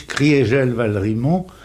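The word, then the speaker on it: Kriegel-Valrimont: On prononce Krigel Balrimont, c'est bien ça, ou Krigel Balrimont? – On prononce Maurice Krigel Balrimont pour marquer que en 18, l'Alsace a cessé d'être germanisée.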